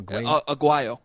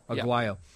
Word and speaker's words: Aguayo: Did I say that right? Aguayo? – Aguayo. – Aguayo.